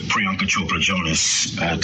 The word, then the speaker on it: Priyanka Chopra Jonas: Priyanka Chopra Jonas, to say it properly.